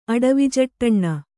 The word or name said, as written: ಅಡವಿಜಟ್ಟಣ್ಣ